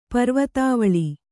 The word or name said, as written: ಪರ್ವತಾವಳಿ